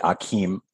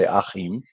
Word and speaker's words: Achim: So Americans say Achim, which is very weird. So I love coming to Deutschland where I can say Achim.